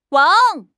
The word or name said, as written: wang3